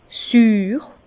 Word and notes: süür: Bas-Rhin; Herrlisheim

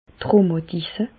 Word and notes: d' Rhùmatisse: Alsatian; Bas-Rhin; Reichshoffen

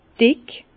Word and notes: dick: Bas-Rhin; Herrlisheim

